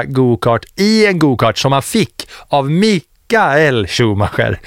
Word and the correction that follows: Michael: Michael. – Ja, ja, förlåt. Jag skäms alltid över mina tyska uttal.